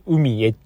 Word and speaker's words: UMIE: ハーバーランドってわかる海へっていうね、小文字のアルファベットで UMIE と書いて、海へっていう商業施設があるんです。